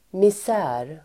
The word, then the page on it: misär: [mis'ä:r]